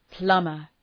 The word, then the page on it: plumber: ‘plʌmər